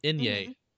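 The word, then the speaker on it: eñe: He said it's pronounced an Inye. But I didn't know it was pronounced an Inye.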